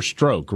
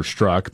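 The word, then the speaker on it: Strzok: Peter Stroke. Remember that? Oh, yes, of course. I think he pronounces it Peter Struck, but I think it's funnier to say Peter Stroke.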